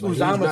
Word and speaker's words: Uzomah: It's Uzama, how you say it.